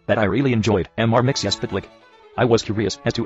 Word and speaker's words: Mxyzptlk: Okay, first of all, the name is spelled Mxyzptlk. Okay, no vowels. So, you put your vowels in, so it's Mix, Mxyzptlk, I-C-K. Mxyzptlk. Mxyzptlk. Mxyzptlk. And when you say his name backwards, when you make him, rather, say his name backwards, that's how you get rid of him and send him back to the fifth dimension for at least 90 days. His name is pronounced Kilt-Pizzi-Ixum. Backwards. Kilt-Pizzi-Ixum. Kilt-Pizzi-Ixum. Kilt-Pizzi-Ixum. So Mxyzptlk, when pronounced backwards, is Kilt-Pizzi-Ixum.